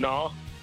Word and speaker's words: gnaw: No, it's a silent G. – You say silent G. Shoot. Something. – Help him out. – Gnaw.